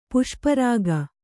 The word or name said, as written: ಪುಷ್ಪರಾಗ